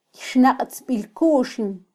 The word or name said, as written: kyxnaqtzbilkuxin